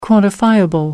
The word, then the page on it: quantifiable: American English